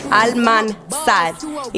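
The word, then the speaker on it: Almánzar: That's how you pronounce it? – Almanzar. Yeah, so Belcalis Almanzar. If you read it Spanish, yeah.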